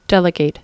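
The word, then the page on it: delegate: verb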